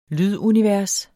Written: lydunivers

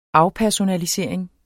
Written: afpersonalisering

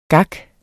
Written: gak

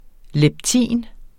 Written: leptin

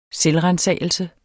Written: selvransagelse

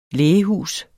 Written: lægehus

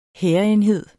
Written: hærenhed

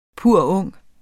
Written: purung

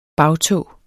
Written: bagtå

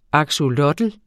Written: axolotl

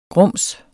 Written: grums

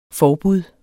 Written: forbud

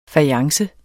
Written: fajance

